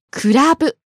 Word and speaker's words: クラブ: 次は、クラブ。 – Nightclub。 – ピッチアクセント気をつけてください。 Watch the pitch accent. Club is? – Club. So for a night spot, the pitch starts low and goes up at the second syllable, da.